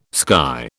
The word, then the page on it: sky: British English